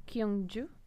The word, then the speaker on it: Gyeongju: Soonie, you grew up in Gyeongju? – Yes. – Is that my pronunciation's okay? I'm so worried about my pronunciation. – That's quite all right.